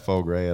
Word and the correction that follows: foie gras: at Otto's. Faux Gras or whatever it's called. I don't know how to say it right. – I'll call it Foie Gras.